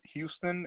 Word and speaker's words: Hewston: It is Houston. It's spelled H-E-W-S-T-O-N.